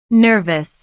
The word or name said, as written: nervous